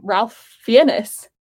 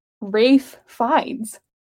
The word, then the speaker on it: Ralph Fiennes: I always pronounced his name Ralph Fiennes, but it's actually pronounced Rafe Fiennes.